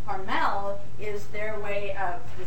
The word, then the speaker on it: Hormel: And he insists that most people mispronounce the name of the company because he says that his pronunciation is correct and it's not Hormel, it's Hormel.